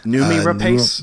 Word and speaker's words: Noomi Rapace: Noomi Rapace. – Noomi Rapace.